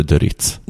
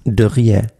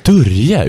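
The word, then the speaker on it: du Rietz: Det uttalas inte Döritz. Dörje. Dörje uttalas det, Och det är enligt jag Ola sett...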